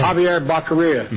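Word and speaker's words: Xavier Becerra: There's a different way to pronounce that name. – Javier Becerra. – Mm-hmm. Correct. Yes. Thank you.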